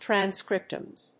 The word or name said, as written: transcriptomes